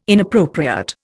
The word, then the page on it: inappropriate: Indian